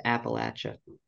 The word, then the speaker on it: Appalachia: It's pronounced Appalachia.